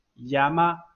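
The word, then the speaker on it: やま: 那我们看一下关于鸭有一个单词哈，第一个假名是读作鸭，第二个假名是读作妈妈妈，那么这个单词怎么读呢？往上读，读作鸭妈鸭妈鸭妈啊，这个意思跟中文的意思是一是一样的，鸭妈。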